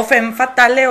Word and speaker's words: Femme Fatale: ¿Fem Fatal o Fem Fatale o cómo lo pronuncio? Fem Fatal. Fem Fatal, Fem Fatal, ok.